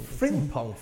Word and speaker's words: Frimpong: Frimpong? Am I saying that right? Frimpong. – Frimpong.